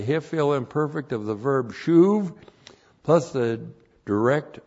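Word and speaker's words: shuv (שׁוּב): and then the verb shuv. S-H, long U-B, shuv.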